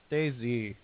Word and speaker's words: DayZ: I think it's pronounced DayZ. – DayZ? Machina? – Yeah. – DayZ? – Really? Yeah, it's DayZ. – DayZ. – Because it's a capital Z.